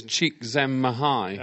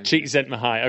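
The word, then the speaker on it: Csikszentmihalyi: Flow by Cheikh Mahelyi, and I hope that I'm pronouncing that. – I believe it's Cheikh Zen Mahai.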